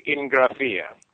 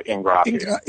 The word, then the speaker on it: Ingraffia: Well, if you were in Italy, you would say Ingraffia. But since we're not, my father pronounced it Ingraffia. So that's what I go by, Ingraffia.